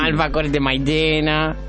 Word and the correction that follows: maicena: Alfajores de maicena. – Maicena, no maitena.